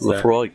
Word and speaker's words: Laphroaig: and Han is holding up a bottle of Lafroig. What is that? How do you pronounce Laphroaig. – That? Laphroaig. Laphroaig. Laphroaig.